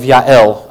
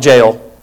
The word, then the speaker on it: Yael: And this morning we are looking at a lady by the name of Yael. I know that looks like Jael. It's Yael. That's how that's pronounced.